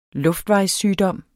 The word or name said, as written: luftvejssygdom